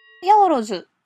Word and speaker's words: ヤオロズ: ヤオロズ、発音はハイローズとかオカモトズと一緒なんですけど、